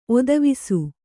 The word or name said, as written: ಒತ್ತವಿಸು